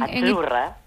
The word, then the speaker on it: Azzurra: Azurra. – Azurra. Mira, ¿ves? – Azurra. Es que... Azurra. Sí, como porque pizza. La Z no es la Z como es decimos en catalán tan sonora, no.